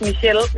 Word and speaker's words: Michelle: Men det er stadigvæk fransk, og det udtales Michelle, uanset hvad.